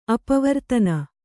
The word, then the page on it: ಅಪವರ್ತನ: apavartana